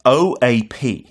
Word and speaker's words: OAP: It's an acronym and this one you don't pronounce like UNESCO 그렇게 단어처럼 읽지 않고 음. OAP처럼 just O A P.